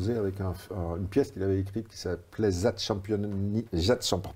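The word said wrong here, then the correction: That Championship Season: Putain, mon anglais de merde Prononce-le sans... – That, Championship Season,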